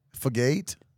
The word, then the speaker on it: Fugate: I think it's Richard Fugate. – No. – Well, there is an E on the end, but it's a long A. Fugate?